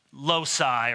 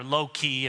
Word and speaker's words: loci: You know, in systematic theology, you have different loci or loci, if you were really pronouncing the Latin correctly, different themes, categories.